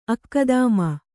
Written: ಅಕ್ಕದಾಮ

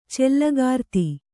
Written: ಚಲ್ಲಕಾರ್ತಿ

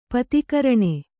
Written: ಪತಿಕರಣೆ

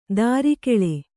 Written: ತಿರುಗುಳಿ

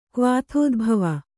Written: ಕ್ವಾಥೋದ್ಭವ

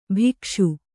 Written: ಭಿಕ್ಷು

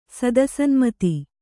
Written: ಸದಸನ್ಮತಿ

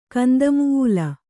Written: ಕಂದಮುೂಲ

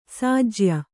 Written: ಸೋಜ್ಯ

